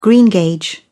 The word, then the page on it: greengage: British English